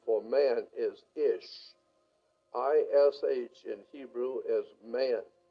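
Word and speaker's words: Ish (אִישׁ): Man in Hebrew is pronounced Ish and looks like this.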